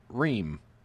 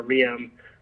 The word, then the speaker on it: Reum: So first of all, let's talk about your last name, because it's spelled R-E-U-M, but you pronounce it Reum. – Yeah, it's pronounced Reum. Everybody thinks it's Room or Reum, but it doesn't make any sense, but it's Reum. So just pretend there's two E's.